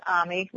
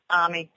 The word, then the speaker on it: Ami: The name is Ami, rhymes with salami. It looks like Amy, but that's not my fault. So it's spelled peculiarly, but Ami, salami, you'll remember it that way.